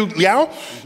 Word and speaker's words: Liao: And we have Matthew Liao.